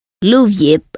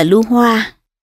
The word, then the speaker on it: Lưu Diệp: Lưu Diệp ở phần trước đã phiên âm là Lưu Hoa, thật ra chữ này đọc là chữ Diệp hoặc Dẹp. Phần mở đầu truyện có ghi, sách Hậu Hán Thư của Phạm Hoa, xin độc giả sửa lại là Phạm Diệp cho đúng.